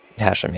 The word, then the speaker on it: Hashem: Yeah, my name is Hashim. – Hashim? – That's Hashim, yeah. That's, I'm sorry, how you spell it? – H-A-S-H-E-M, as in mother at the end. – Okay, Hashim. – Hashim, yes.